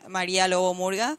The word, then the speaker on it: Maria Lobo Murga: I have my Instagram, I'm on LinkedIn, and my Facebook and all that with my name, Maria Lobo Murga. – We all know how to spell that. – Okay. – All right. Maria, M-A-R-I-A, Lobo, well, that's how I say it.